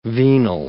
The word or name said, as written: venal